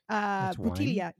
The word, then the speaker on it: bottiglia: I think it's called bottiglia. – Bottiglia. – Yeah, for a bottle of wine. Yeah, bottiglia. And so it's spelled Bottiglia. Bottiglia.